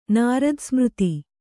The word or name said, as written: ನಿರೋಧಸಮಾಧಿ